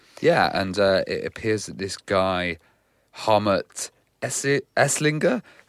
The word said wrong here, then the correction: Hartmut Esslinger: sorry, my pronunciation's awful. – Hartmut Esslinger. Esslinger, yeah.